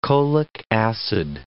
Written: cholic acid

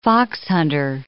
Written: foxhunter

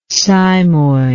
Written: cymoid

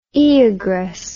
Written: Oeagrus

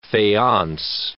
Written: faience

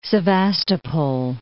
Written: Sevastopol

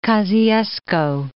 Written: Kosciusko, Mount

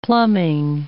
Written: plumbing

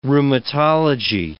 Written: rheumatology

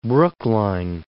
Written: Brookline

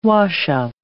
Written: washup

